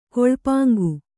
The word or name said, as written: ಕೊಳ್ಪಾಂಗು